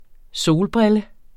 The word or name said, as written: solbrille